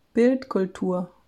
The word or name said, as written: Bildkultur